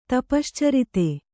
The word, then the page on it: ತಪಶ್ಚರಿತ: tapaścarita